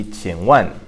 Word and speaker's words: 请问: And so that would be 请问. So that's pronounced third tone, fourth tone, 请问.